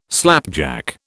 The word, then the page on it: slapjack: British English